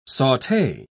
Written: saute

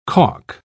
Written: caulk

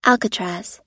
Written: alcatraz

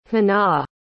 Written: honaw

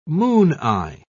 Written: mooneye

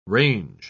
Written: range

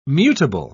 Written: mutable

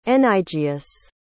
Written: enygeus

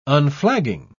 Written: unflagging

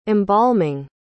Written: embalming

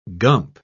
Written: gump

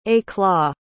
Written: éclat

